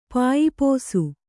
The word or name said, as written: ಪಾಯಿಪೋಸು